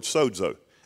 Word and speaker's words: sozo: This word here is actually a different word, but from the Greek word sozo. S-O-Z-O, pronounced S-O-D-Z-O, sozo, Greek.